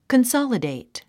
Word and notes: consolidate: kənsάlədèit; カンサラデイト

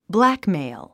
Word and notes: blackmail: blǽkmèil; ブラックメイゥ

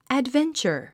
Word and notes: adventure: ædvéntʃə r; アドベンチャァ

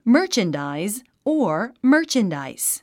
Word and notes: merchandise: mə́ː r tʃəndàiz; マーチャンダァイズ

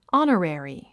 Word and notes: honorary: άnərèri; アナレリィ

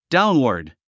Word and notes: downward: dáunwə r d; ダウンワード

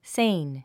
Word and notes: sane: séin; セイン

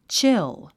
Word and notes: chill: tʃíl; チゥ

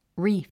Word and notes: reef: ríːf; リーフ

